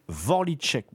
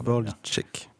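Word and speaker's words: Vorlíček: Je ne sais pas exactement comment on prononce ça à la fin. Un film de 1973 qui est aussi lié en partie à la zone de l'URSS, il a été produit. – Vorlitschek, mon cher.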